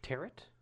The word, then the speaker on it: tarot: Why is the T silent? – Is it French? – Tarot? Tarot. Yeah, it's tarot.